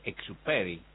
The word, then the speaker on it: Saint-Exupéry: Bueno, vos sabés que un día como hoy, en el año 1944, desaparece Antoine de Saint-Exupéry, en realidad ese es Exupéry, ¿no? Que pasa que es lo traigo a la pronunciación en española, pero en realidad es bastante más complejo pronunciar su apellido.